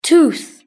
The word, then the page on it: tooth: British English